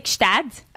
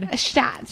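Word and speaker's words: Gstaad: Well, in French we say Gstad. – Okay, say it in French. – Yeah, that was really hard. – Gstad. – So there's a G. So Gstad.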